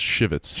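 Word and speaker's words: Chivettes: Female members are, I think, called Chivets. I'm not really sure if male members have a specific name of their own. – I believe own. – It's pronounced Shivets.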